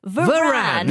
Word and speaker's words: Varane: Rafa Varane, how do you say his name? Rafa Varane. There we go.